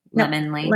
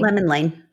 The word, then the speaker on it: Lemon Lane: Not like Limon. Lemon Lane. – Lemon Lane.